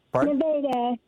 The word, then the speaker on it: Nevada: Thank you, Nevada, Missouri. Am I saying that right? – Nevada. Yes, Nevada.